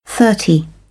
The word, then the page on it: thirty: British English